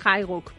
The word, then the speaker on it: Highbook: Eh, te has traído CEO de Highbook ¿Lo digo bien, Haiguk? – Solemos decir Haiguk, pero ya estamos acostumbradas.